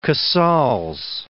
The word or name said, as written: Casals